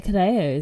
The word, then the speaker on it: Cadejo: Yeah, well, they don't, it's not, they don't say J, do they? They pronounce it like a H. – Yeah. – Okay, so the Cadeo is a supernatural creature from Central American folklore.